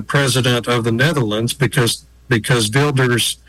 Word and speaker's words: Wilders: It's spelled Wilders, but is pronounced Wilders, Geert Wilders.